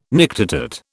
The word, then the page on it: nictitate: British English